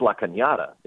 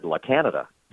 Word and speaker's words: La Cañada: Well, it's pronounced La Cañada. It's a Spanish word, and that's the name of the high school. And I went on the air and said La Canada.